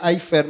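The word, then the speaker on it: aifer (אפר): Y eso es que la palabra este, aifer, ahí está, Este palabra hebreo se pronuncia aifer más o menos, significa tanto polvo como tierra, como cenizas, como barro.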